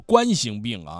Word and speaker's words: 冠心病: 韩小杨说今天听磊哥的节目才明白读作冠心病啊，一直自己都读冠心病啊。这其实是个多音字问题，正好有几分钟咱们简单聊一下，既然这个韩小杨提到这个事儿了。冠这个字它有两个读音，一个读作冠，另外一个读作冠。